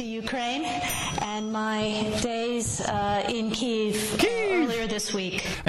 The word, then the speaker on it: Kyiv: And by the way, before you play, so people can keep their ear out, we've gotten three notes so far confirming that her pronunciation of Kiev is correct. – Yes. It's Kyiv, yes.